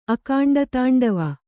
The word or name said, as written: ಅಕಾಂಡತಾಂಡವ